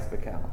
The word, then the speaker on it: Bakel: Who is Tobias Bakel? – Who is Tobias Bakel? Hi, my name is Tobias Bakel, it rhymes with hell.